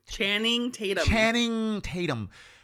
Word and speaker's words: Channing Tatum: Chantum, how you say his name? – Channing Tatum.